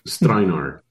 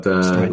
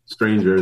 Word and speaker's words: Strajnar: Very close. Yes. Strajnar. But Strajnar, look, I've heard it all. Stranger, Steinlager, yeah.